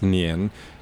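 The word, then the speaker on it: nian (年): You know, the character for year is pronounced nian, and the character for sticky is also pronounced nian. – And new sticky. – Right, new year sticky. So they're both pronounced the same way.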